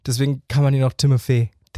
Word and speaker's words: Timothée: Tatsächlich, also er hat es selber in Interviews auch gesagt, richtig ausgesprochen wird sein Name Timothée. – Timothée? – Timothée. Ich habe leider nicht so einen guten französischen Akzent, aber Timothée ist die richtige Aussprache.